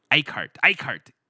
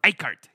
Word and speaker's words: Eickert: But so let's kick into my conversation with the Academy Games boys, the Eckhart boys. Eichhart, E-I-C-K-E-R-T. Is that how you spell it? Eichhart, yeah.